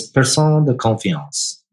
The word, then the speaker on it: personne de confiance: It's French, and it I learned it from Jay Hughes, who's been a mentor of mine for probably more than 30 years. But it's personne de confiance versus the other idea is a personne d'affaires, person of affairs.